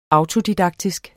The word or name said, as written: auto-